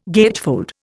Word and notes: gatefold: Indian

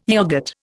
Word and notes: yoghurt: Indian

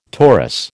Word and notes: Taurus: American English

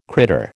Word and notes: critter: American English

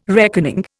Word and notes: reckoning: Indian

